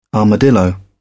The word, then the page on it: armadillo: British English